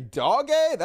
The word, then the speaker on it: Dogecoin: Dogecoin, is that how you pronounce it? That makes a lot more sense. It's pronounced uh, Dogecoin? Really? Doggy coin? Is that really how it's pronounced? Doggy? Okay, I wasn't even close. Doggy, not Doige. Okay, Doge.